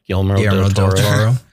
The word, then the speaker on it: Guillermo del Toro: Yeah, I love me a good Guillermo del Toro. Did I say that right? Guillermo del Toro.